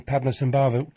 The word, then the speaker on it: Padmasambhava: Michael Armstrong was a producer I knew, and I sat next to Bowie, and he said, by the way, you're pronouncing Padmasambhava correctly.